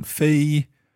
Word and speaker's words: 非: Okay, so the first one is the high one. Fei, that's first tone.